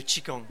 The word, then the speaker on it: qigong: Le qigong.